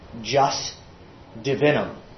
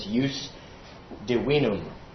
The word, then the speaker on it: jus divinum: It looks like, and it's said when anglicized, jus divinum, or with one of the ways of pronouncing it with Latin pronunciation is jus divinum.